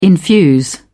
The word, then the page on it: infuse: British English